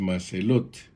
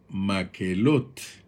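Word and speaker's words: Makhelot: Hoy la jornada es Macelot, que en el idioma hebreo se pronuncia Maquelot. Como ya vimos y podemos ir notando, hay una pronunciación similar con Celata, porque Celata nos lo traducen a nosotros, pero en hebreo es Keelata, así como hoy Macelot nos lo traducen a nosotros en español, pero la pronunciación es maquelot.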